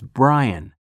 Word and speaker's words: Bryan: K-E-R-R-Y. – 4. – What's your name? – It's Brian. B-R-Y-A-N.